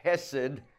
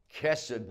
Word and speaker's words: chesed: It's the word hesed, H-E-S-E-D, or chesed, which is a guttural sound, C-H-E-S-E-D or C-E-D.